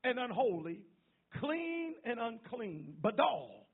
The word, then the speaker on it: badal: Difference is the Hebrew word badal, is how you pronounce it.